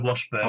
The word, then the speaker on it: Washburn: And it was this Mashburn, is it? M A Washburn? No, Washburn. – Oh, Washburn. Washburn. Oh, spelt with a W, though.